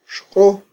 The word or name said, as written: xq'o